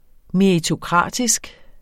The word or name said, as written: meritokratisk